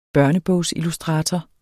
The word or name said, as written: børnebogsillustrator